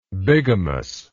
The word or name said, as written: bigamously